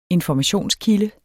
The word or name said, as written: informationskilde